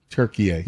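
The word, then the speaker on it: Türkiye: Turkey A. – Yeah. Turkey A. Yeah. There we go. Turkey A. – I don't know. – Turkey just decided to change the pronunciation of their country about 2 years ago.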